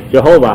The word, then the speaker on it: Jehovah: Because the first syllable is really not a J sound, but a Ye sound. – 那你们记得啊？你们要赞美耶和华，英文怎么说？You ought to praise Jehovah.